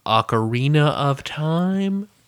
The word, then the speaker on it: Ocarina of Time: The Ocarina of Time? – You said it right.